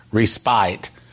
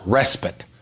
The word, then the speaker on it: respite: Somebody emailed me and said, by the way, you don't pronounce it respite. It's pronounced respite.